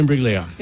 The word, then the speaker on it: Imbruglia: Imbrigula. – Natalie Imbruglia. Put it down. I'll give you that. – Soft G. – Soft G. – Imbruglia.